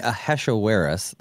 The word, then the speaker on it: Ahasuerus: Well, it is so fascinating because, you know, King Ahasuerus, that's how you pronounce his name. I had to practice a lot. No wonder that most people just call him Xerxes, which is his Greek name.